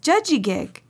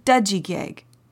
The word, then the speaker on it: judjigig: Which reminds me, the Mi'kmaq word for healthy is judjigig, or some people pronounce the word dudjigig. See how close they are? Do you hear the difference? Judjigig and dudjigig.